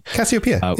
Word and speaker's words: Cassiopeia: So the Cassiopeia, I think I'm saying that correctly. – Cassiopeia. Yeah.